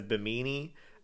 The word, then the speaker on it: Bimini: Except I think it's properly pronounced as Bimini. – Yeah, that's how her name is pronounced.